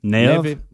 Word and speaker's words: Neve: N-E-V-E. How do you say it? – Nev. – Nev Campbell.